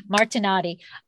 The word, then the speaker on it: Martinotti: Martinotti.